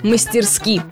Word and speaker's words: мастерски: Итак, по нормам великого и могучего правильно ставить ударение на последний слог, то есть мастерски.